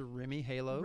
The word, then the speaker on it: Rheem Halo: What's the difference between those and something like a Rheem Halo as far as air purification goes? I say that correctly? – It's a Rheem Halo.